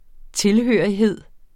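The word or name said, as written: TILHØRIGHED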